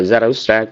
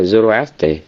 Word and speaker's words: Zarathustra: Tiếng Đức thì gì đó đọc là Zarathustra, còn tiếng Pháp nó là Zoroastre.